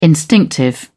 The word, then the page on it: instinctive: British English